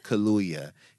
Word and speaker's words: Kaluuya: Want to give a quick shout out to Mashable, who tweeted that Get Out star Daniel, how do you say his last name? – Kaluuya.